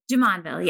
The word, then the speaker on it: Jumonville: We are on the road this week, and we are at beautiful Jumonville. Did I say that right? – Jumonville, yes. – Jumonville in western Pennsylvania.